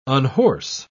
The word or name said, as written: unhorse